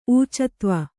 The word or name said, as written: ಊಚತ್ವ